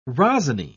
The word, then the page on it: rosiny: British English